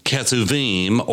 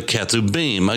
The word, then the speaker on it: Ketuvim: and that's where we get the N in T-N-K. And then you have the Ketuvim or the Ketubim.